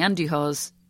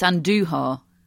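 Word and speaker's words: Andújar: I've just realised, uh, courtesy of Matt, that I've been pronouncing Andujar's name wrong for the entirety of his career, which is a very long time. So it's Andujar, not Andujar, Matt.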